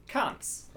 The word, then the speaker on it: Conks: Conks. Like concentration.